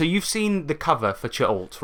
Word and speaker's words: Cha'alt: So what we'll do, we'll get into a bit of Ch'alt now, which he says is pronounced Ch'alt or however you want to pronounce it. That's what he said. – I like that. I'm going to pronounce it Venger Satanis' Magical World of Fun. – No. – Is that right? No. Okay. – No, stick with Ch'alt.